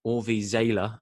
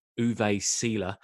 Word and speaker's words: Uwe Seeler: just as I was reminded of the fact it should be pronounced Orvi Zela and not Uwe Seeler, as I did in the previous podcast on Peter Benetti.